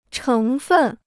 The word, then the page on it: 成分: chéng fèn